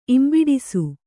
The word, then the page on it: ಇಂಬಿಡಿಸು: Kannada